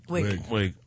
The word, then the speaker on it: Wiig: Wiig. Oh, whatever. – It is spelled weak. It is weak. – Spelled weak. It's not. – If you're from Sweden, it's spelled weak.